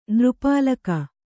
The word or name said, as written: ನೃಪಾಳಕ